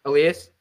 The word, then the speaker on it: Elias: So if you use the LTIR space, you could go after a guy like Elias Lindholm. I don't know if the right. No, you had it right the first time, Elias. – Elias? Okay, I was right. – Elias Lindholm, yeah.